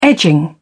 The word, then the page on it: edging: British English